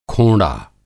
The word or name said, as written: खोंड़ा